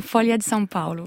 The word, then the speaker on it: Folha de São Paulo: La Folha de São Paulo.